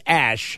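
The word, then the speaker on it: æ: that a tilted A connected to an E, which is pronounced Ash.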